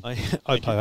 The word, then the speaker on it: Oppo: Oppo, actually, but anyway. – Okay, right. – It's pronounced Oppo.